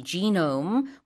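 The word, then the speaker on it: genome: 연구 어떤 협력단을 구성해서 여러 가지 지노움, 우리는 이제 게놈이라고 하는데 영어 발음으로는 지노움들을 analyzing, 분석했습니다.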